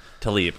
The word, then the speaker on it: Taleb: God, I'm Taleb. so bad at names. Taleb.